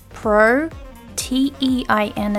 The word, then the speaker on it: protein: P-R-O, pro, T-E-I-N is pronounced as teen here, protein.